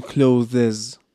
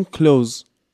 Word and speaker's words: clothes: ما نمیگیم clothes میگیم کلوز